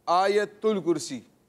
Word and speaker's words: آیت الکرسی: یا کے اوپر بھی زبر ہے, یا کے اوپر زبر ہے, تا کے اوپر پیش پڑھنی ہے, آیت الکرسی. تو اس کا تلفظ بھی درست ہونا چاہیے.